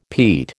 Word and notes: peat: American English

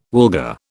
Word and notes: wilga: British English

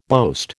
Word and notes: boast: American English

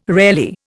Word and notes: rarely: Indian

